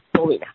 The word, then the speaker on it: Polina: Polina Vasiliev. – Polina, okay. – Yeah, Polina. P-O-L-I-N-A.